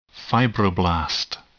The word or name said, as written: fibroblast